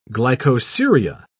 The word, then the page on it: glycosuria: British English